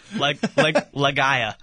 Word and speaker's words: Legia: I don't know if they pronounce their E's like the Spaniards do. – Legia Warsaw. – Whatever's the dumbest pronunciation. – Legia. Legia Warsaw. – Legia. – Yeah, Legia Warsaw.